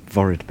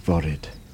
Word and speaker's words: Foryd: I'm looking across mudflats towards across a bay, basically, called Vorid Bay. Actually spelt with an F, but in Welsh, F's are V's, and this is F-O-R-Y-D, Vorid,